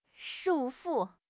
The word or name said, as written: 束缚